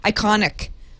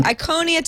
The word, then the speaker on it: Iconia: God, I can't say that right. I said that wrong earlier. I called it the Iconic which I think would kind of be cool.